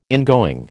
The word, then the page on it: ingoing: American English